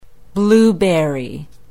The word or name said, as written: blueberry